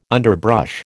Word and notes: underbrush: American English

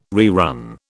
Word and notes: rerun: British English

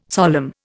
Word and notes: solemn: Indian

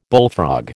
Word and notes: bullfrog: American English

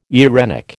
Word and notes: eirenic: American English

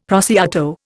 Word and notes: prosciutto: Indian

